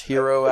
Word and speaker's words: gyro: I started reading that like I was reading the word gyro, which is also pronounced hero, actually. So, but I always pronounce it gyro because I'm stupid.